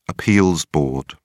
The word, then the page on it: appeals board: British English